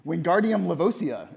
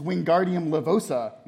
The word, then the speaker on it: Wingardium Leviosa: You're saying it wrong, Harry heard Hermione snap. It's Wingardium Leviosa. Make the gar nice and long.